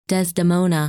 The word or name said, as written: Desdemona